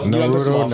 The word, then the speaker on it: Naruto: I said it wrong. How do I say it? – No, Naruto. Naruto.